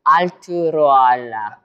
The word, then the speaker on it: Arturaola: Arturaola.